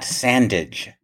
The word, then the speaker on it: Sandage: Uh, history professor Scott Sandage, rhymes with bandage, by the way, I've been advised, who corresponded with us earlier about the One More Sweet Curio uh, mm-hmm.